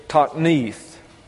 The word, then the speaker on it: toknit: The Hebrew word here is tokneith. That's spelled T-O-K-N-I-T, pronounced tokneith.